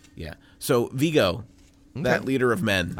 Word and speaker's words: Viggo: No, it's Viggo. – Are you sure? – Yes. Viggo Mortensen is how you say his name. – He's got a lot of vigor? – No.